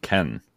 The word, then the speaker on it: Cannes: Oh, it's telling me, Google's telling me that it's actually pronounced Ken. – Ken? – Yeah. Take that, you con. – The Cannes Film Festival, I don't know. – It's actually pronounced Kennedy. – Yeah, y'all don't know shit either. – I was asking. I was trying to get clarity. Yeah, I didn't know. – I thought it was Cannes. I was certain that it was Cannes.